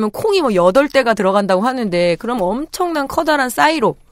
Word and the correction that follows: 사일로: 정은정 농축산이 농축산이 어른 발음을 한 저것은 사일로겠죠. 사일로.